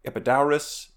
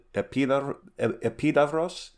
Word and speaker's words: Epidaurus: Then we'll swing down to the Peloponnese, and then go to like this very well-preserved ancient theater at Epidaurus, or I think the more Greek way to pronounce it, Epidavros.